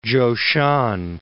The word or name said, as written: Chou-shan